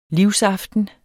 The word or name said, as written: livsaften